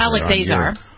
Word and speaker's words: Azar: Alex Azar.